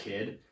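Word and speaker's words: K?D: Um, there's an artist called Qu- K question mark D, and he it's pronounced Kid.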